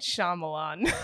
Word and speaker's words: Shyamalan: What you'd probably find fun is, as a composer, he's known for working very closely with Shyamalan. For people... – Why you gotta disrespect the man's name like that? – Sorry for... Oh, man, I can't even say it correctly now. – M. Night Shyamalan.